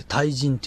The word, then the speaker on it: 大人: えー、中国では、あの、大人って書いて対人って読むんだけど、そういう、ちゃんとした大きい人という意味ですよ。